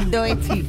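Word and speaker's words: Doechii: Doechi.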